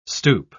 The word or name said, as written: stoop